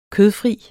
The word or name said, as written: kødfri